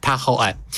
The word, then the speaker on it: Tahoe: natomiast jest tam ciche, niewymawiane E, Tahoe.